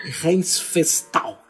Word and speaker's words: Riefenstahl: que é Leni Riefenstahl. Tá aceitável seu alemão.